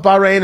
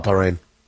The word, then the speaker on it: Bahrain: Do we just want to call it Bahrain and not give it the ch? We can do that. We'll just call it Bahrain. It's fine. The UAE and Bahrain are signatories to the Abraham Accords, which is about normalizing relations with Israel.